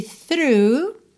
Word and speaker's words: through: Então, olha, esse through, que é fácil de pronunciar, às vezes é um pouco difícil ligar que essa escrita aqui, T-H-R-O-G-H, soa through, tá?